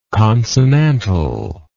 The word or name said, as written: consonantal